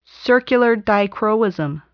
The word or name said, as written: circular dichroism